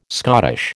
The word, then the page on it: Scottish: American English